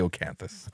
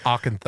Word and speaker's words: Acheron: No, it's definitely Acheron. – It's Acheron.